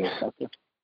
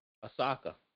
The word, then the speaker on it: Osaka: All right, I just want to talk about this one thing real quick about Naomi Osaka. Did I say it right? – Naomi Osaka. – Osaka. There we go.